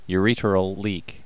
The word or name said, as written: ureteral leak